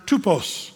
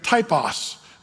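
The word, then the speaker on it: tupos (τύπος): The Greek word there is, you'd pronounce it tupos, but we might say typos. That's the way we would say it, typos.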